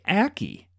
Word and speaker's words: ackee: A-C-K-E-E. Aki? – A-C-K-E-E? – Mm-hmm. – I mean, I would just say Aki.